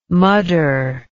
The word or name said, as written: mudder